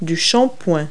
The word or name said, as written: shampoo